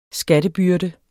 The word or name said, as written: skattebyrde